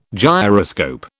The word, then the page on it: gyroscope: British English